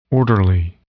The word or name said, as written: orderly